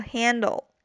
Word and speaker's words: handle: 再就是注意后边这个 handle，注意这个词的发音，中间有一个梅花 a，发这个音的时候呢，张嘴一定要大一些。